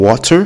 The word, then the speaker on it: water: So, in, at least in America, in the United States, we say water. Instead of saying water, people say water. But the pronunciation, the T turns into like a D. So it's like water, wad, water, water, right?